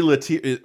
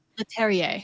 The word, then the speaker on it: Leterrier: Louis Latier. Uh, how do you say his name? – Leterrier.